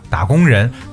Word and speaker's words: 打工人: 大家在读的时候不要把它读错了哈，打工人不要读成打。